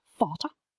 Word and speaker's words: Vater: The letter V in German sounds like the English F. So Vater, meaning father, is pronounced Vater.